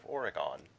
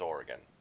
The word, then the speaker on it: Oregon: Well, to the lovely state of Oregon, which is not pronounced that way. It's Oregon. Don't let them hear you pronounce it that way.